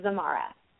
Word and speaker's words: Zamara: So the way you say my name is actually Zamara. So phonetically, it's like a Z-A-M-A-R-A.